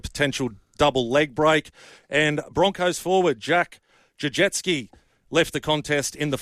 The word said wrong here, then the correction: Gajewski: What did you say his name was? – Jack Gajewski. – Gajewski. – Gajewski. – Left in the final minutes for an HIA. – I thought you said Jack Jajewski.